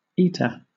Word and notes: eta: British English

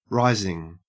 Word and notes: rising: Australian English

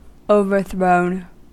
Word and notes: overthrown: American English

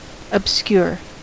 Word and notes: obscure: American English

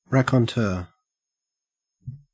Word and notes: raconteur: Australian English

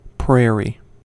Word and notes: prairie: American English

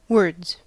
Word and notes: words: American English

